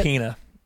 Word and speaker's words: Pena: Is that how you pronounce it? – Pena. That's how he says it.